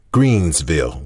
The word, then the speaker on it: Greensville: On the map, it reads Greenville, but we always pronounced it Greensville with an S.